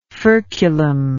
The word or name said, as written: furculum